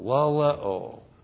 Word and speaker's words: laleo: Now, the subject is God, and the verb is laleo. L-A-L-E long O, laleo.